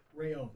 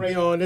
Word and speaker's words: Rayon: Rayon. – Tell me how you say your name is Rayon. – It's Rayon. It's actually French, but Rayon is the Spanish name.